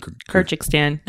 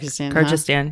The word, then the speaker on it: Kyrgyzstan: And also he said he really enjoyed Sam's trip to, how do you pronounce it? – Kyrgyzstan. That's how I say it. Kyrgyzstan.